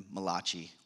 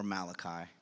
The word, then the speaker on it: Malachi: and Malachi, or Malachi, depending on how you say it.